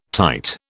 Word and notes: tight: British English

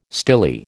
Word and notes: stilly: American English

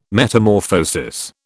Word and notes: Metamorphosis: British English

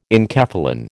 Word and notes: enkephalin: American English

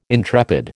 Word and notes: intrepid: American English

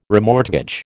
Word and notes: remortgage: American English

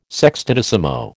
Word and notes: sextodecimo: American English

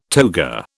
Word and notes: toga: British English

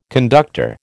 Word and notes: conductor: American English

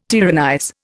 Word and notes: tyrannize: Indian

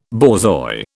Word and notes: borzoi: British English

